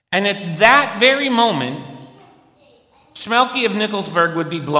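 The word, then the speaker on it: Shmelke: Schmielke is probably a better way to pronounce it.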